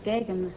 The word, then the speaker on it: Geoghegan: It's pronounced Gagan, Mr. Godfrey. – Gagan? Mm-hmm. G-E-O-G-H-E-G-A-N comes out Gagan.